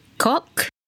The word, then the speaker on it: kock: It is spelled K-O-C-K. Kock. It is important to take note of this. So the word is spelled K-O-C-K. So the O in this word is followed by the two consonants C and K, which makes the O sound short. Like in the English word stock, lock, or dock, as in docking, D-O-C-K-I-N-G.